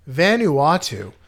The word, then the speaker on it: Vanuatu: Vanuatu. See, it helps if you don't say it completely wrong. – It says if you say it, it helps if you say it right.